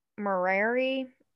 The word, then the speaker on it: Murray: at Fashion Place Mall in uh, Murari, I think is how you say it. Sounds right. Yeah.